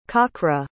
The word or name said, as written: kakra